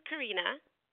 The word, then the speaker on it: Karina: My first name is Karina.